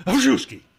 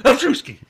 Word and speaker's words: Krzyzewski: So it's Mike Krzyzewski. Isn't that how it works? – How is that again? – Krzyzewski.